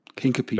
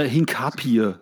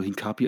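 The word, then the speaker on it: Hincapié: Hinkepie, ja. glaube ich, ist, der einfach, ist die einfache Aussprache, so wie man es schreibt. Hinkepi. Hinkepi. Ja. Das klingt aber wie ein Pokémon. Hey, ja. Hinkepie kommt. Oder Hinkapie sagen auch viele, aber ich glaube, Hinkapie ist völlig übertrieben falsch ausgesprochen.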